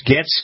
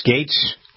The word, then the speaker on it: Gaetz: All right, Matt Getz. Getz, Gates. G-A-E-T-Z, Gates, you pronounce it, right?